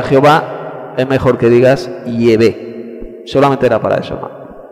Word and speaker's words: Yahvé: en vez de decir Jehová, pues no diga Jehová, pues di la pronunciación más probable, que es Yahvé.